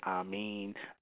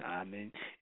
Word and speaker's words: Amun: You know, you might have people pronounce it a little bit, Amin, Amun, Amun, Amun. It's pretty much stayed the same. That was this man's mispronunciation. But it's Amun.